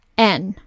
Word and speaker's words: Louann: Make sure you spell that name right, because people, I feel like it's not the best word to spell, but it's Louann, just to be clear.